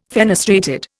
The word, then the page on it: fenestrated: Indian